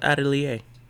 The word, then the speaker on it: atelier: Oh, it's pronounced atelier.